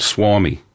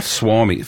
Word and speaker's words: Suomi: It's actually called Suomi. – Called what? – Suomi. Finland is spelled S-U-O-M-I.